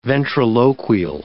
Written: ventriloquial

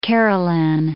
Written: Kerulen

